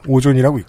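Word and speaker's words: O3ohn: 이거 어떻게 읽는지 모르겠습니다. 오존이라고 읽습니다.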